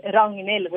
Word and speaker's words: Ragnhild: So because Reginhild almost, it's a very difficult name, so a lot of English people and international people struggle to say Ragnhild, which is how you say it in Norwegian.